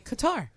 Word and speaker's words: Qatar: Cutter. You would say Qatar.